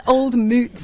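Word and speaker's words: Old Mout: The most mispronounced cider in the world. It's Old Moot Cider.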